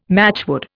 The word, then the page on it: matchwood: Indian